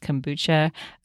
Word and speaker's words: kombucha: Now, fermented foods include things like yogurt, not all yogurt's created equal, but quality yogurt, kefir, also pronounced kefir, kombucha, also pronounced kombucha, sauerkraut, I think there's just one pronunciation for that, kimchi, you know, lots of fermented foods from all over the world.